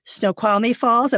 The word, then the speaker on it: Snoqualmie Falls: And then there's Snoqualmie Falls. Am I pronouncing that correctly? – Yes.